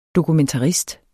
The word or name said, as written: dokumentarist